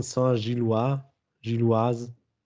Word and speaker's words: Saint-Gilloise: Union unter der Woche zu Hause gefordert in der Euroleague gegen Union Saint-Gilloise. Ich glaube, so wird es eher ausgesprochen.